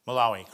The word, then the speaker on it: Malawi: Is it Malawi? – Malawi, correct.